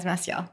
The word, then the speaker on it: Massiel: So Massiel is how you pronounce the name, right? – Yes, okay, Massiel. Good.